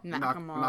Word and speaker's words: Nakamura: Shinsuke Nama- Nakamura. Nakamura.